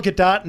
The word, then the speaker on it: Gadot: And it is Gal Gadot, not Gal Gadot, right? – You pronounce the T at the end.